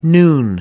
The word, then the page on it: noon: American English